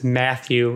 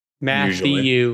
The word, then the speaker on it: Mathieu: So next we have Jacob Mathieu, M-A-T-H-I-E-U is Matthew, right? Matthew, yes.